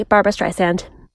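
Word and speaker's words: Barbra Streisand: Anyway, this has been Her Majesty Barbara Streisand.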